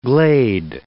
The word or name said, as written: glade